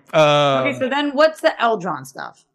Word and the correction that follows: Elrond: It's Elrond.